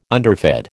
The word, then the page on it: underfed: American English